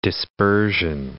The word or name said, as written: dispersion